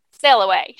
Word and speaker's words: Selah Way: I always say that wrong. I think it's because of the Sail Away. – But it's Sail Away. And I do. I get that song in my head after I see your boat name. But it's spelled, for those of you who are wondering why I keep stumbling over something as simple as Sail Away, it is spelled S-E-L-A-H.